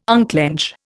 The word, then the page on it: unclench: Indian